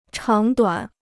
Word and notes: 长短: cháng duǎn

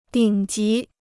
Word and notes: 顶级: dǐng jí

